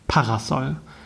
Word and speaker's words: Parasol: Zumindest in meinem Kopf liegt die Betonung auf der ersten Silbe. Parasol.